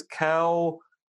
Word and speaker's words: COINTELPRO: I guess it was Cointelpro. There we go. We now have a de facto pronunciation of the program. It is the Cointelpro.